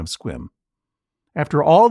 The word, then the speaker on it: Sequim: For his violent, albeit reasonable, mispronunciation of sequim. The E is silent. – Oh. – It's effectively a one-syllable word and is pronounced squim.